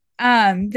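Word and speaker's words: Hermès: Yeah, like she invited their Hermie, how do you say it? Hermes, Hermes. – Hermes.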